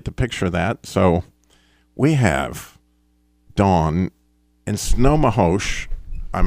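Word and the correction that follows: Snohomish: Good morning, and you have it wrong. There are three syllables there. – Okay. – S-N-O for snow, H-O for hoe, and M-I-S-H for Mish. Snow, oh, Mish.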